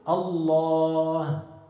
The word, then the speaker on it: الله: هذه الألف تلفظ ولا تكتب. كيف بتكتب لفظ الجلالة؟ ألف لام لام هاء. تكتب شيء بين اللام واللام ألف؟ ما بتكتب، لكن بتلفظها، بتقول الله. فهؤلاء يبدأون بذكر الله تعالى صحيحا، بيقولوا الله الله، بعد قليل يقولون الله الله الله، حذفوا الألف.